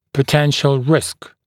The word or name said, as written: potential risk